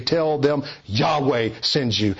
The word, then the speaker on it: Yahweh: This is the covenantal name of God revealed through the four Hebrew letters which we in English use YHWH, pronounced Yahweh.